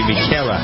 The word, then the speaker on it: Michaela: Blake made a pretty quick decision here on his battleground winner, and it turned out to be Michaela. That's how you pronounce it, M-I-C-H-A-E-L-A.